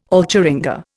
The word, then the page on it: alcheringa: Indian